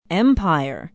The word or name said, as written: empire